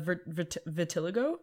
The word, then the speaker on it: vitiligo: And mine has a vitiligo? Vitiligo? I never know how to pronounce that. – Vitiligo.